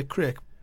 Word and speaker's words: craic: You wouldn't say crake? – No, because it's spelt C-R-A-C-K. – Yeah. – Crake is spelt C-R-A-I-C, crake.